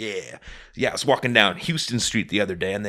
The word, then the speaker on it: Houston: You know that street in, there's a street in New York called Houston, and it's literally just Houston. But it's just not pronounced that way.